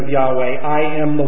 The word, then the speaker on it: Yahweh: These words in Hebrew come from the Hebrew word without vowels in it, which is spelled YHWH, which is Yahweh. It's pronounced Yahweh.